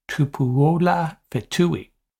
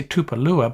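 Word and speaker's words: Tupuola-Fetui: Let me try that again Zion Tupuola Fetui. You want to say Tupalua, but I do not think that's the right pronunciation.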